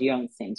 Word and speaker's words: Jorgensen: Yeah, so you can find me on my website and it's Claire Ifua, and I'll say the way it's spelled, Jorgensen, but it's pronounced Jorgensen.